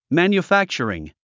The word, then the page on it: manufacturing: mæ`njufǽktʃ ə riŋ; マニュファクチャリン